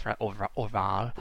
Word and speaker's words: Orval: That's probably not how you say it. It's Orval.